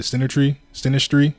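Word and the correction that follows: synastry: chart, sinastry chart.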